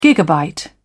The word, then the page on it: gigabyte: British English